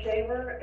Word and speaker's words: Shaver: My name is Victoria Shaver, and that's spelled S-H-A-V-E-R.